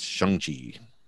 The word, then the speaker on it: Shang-Chi: He corrected the pronunciation, which I've always pronounced wrong. So it's Shang-Chi.